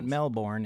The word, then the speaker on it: Melbourne: Melbourne. – Yeah. – And for those Americans, it's not pronounced Melbourne.